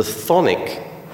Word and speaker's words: chthonic: So in his thinking about mind and earth, Jung invited us to consider what he called the thonic portion of the psyche. Thonic is spelled C-H-T-H-O-N-I-C. It's a Greek word, and it comes from the Greek word thonos, meaning the depths of the earth.